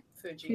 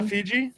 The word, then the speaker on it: Fuji: And it's more Fuji? – Fuji. – It's not Fiji? – Fiji is an island. – Fiji is an island. – Okay. – Yeah. – Mount Fuji.